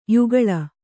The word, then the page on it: ಯುಗಳ: kannada